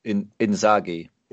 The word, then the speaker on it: Inzaghi: Is it Ngazi? Is that how it's pronounced? – Inzaghi. – Inzaghi. Yeah. Sorry, Simone Inzaghi.